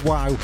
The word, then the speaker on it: Guau: Spelt G-U-A-U, pronounced WOW.